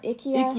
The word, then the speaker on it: IKEA: Germany, they call it IKEA. – IKEA. – We pronounce it wrong. It's actually IKEA.